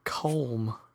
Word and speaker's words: Colm: C-O-L-M. – Calm. – Calm. – Like Calm Meany, but not quite. – Fjord. – Oh, got it. – F-E-O-R-E.